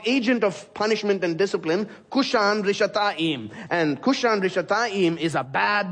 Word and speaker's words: Cushan-Rishathaim: In Hebrew, Mesopotamia there is the longer name is given, which is Aram-Naharaim, which rhymes with his name, Cushan-Rishathaim.